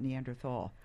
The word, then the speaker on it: Neanderthal: Why is it Neanderthal and not Neanderthal? – The German pronunciation would be a silent H, so Neanderthal.